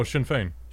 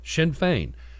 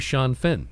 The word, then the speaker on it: Sinn Féin: The pronunciation on this has changed from the day it began till now. Pronounce the Irish. – Oh, Sinn Féin. – Sinn Féin. It used to be Sean Finn.